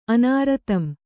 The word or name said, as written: ಅನಾರತಂ